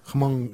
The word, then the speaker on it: Hmong: Yeah, Hmong with the H. It's like a silent H.